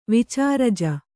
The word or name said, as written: ವಿಚಾರಜ